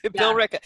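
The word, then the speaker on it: Billerica: Bill Ricca. It's spelled Biller Ica. – Yeah, right. – You know, Bill or Bill Ricca, but Bill Ricca. – Just people are like, no ER.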